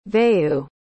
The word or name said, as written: vayu